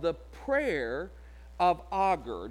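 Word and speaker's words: Agur: Now, I try to remember how to say it because it sounds kind of like author. That's a name, art, you know, author. I just go Augur and just kind of take out the th sound.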